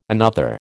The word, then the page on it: another: American English